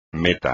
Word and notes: meta: Spanish

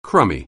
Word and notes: crummy: anglais

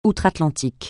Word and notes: outre-Atlantique: francais

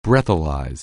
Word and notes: breathalyze: anglais

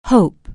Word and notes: hope: anglais

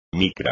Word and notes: micra: Spanish

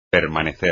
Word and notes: permanecer: espagnol

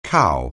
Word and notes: cow: anglais

